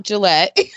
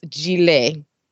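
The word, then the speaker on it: gilet: Okay, this has nothing to do with shaving brand, so it's not Gillette. – I would have gone with that. – Yes. The ET at the end is a long A, so it's Gillette.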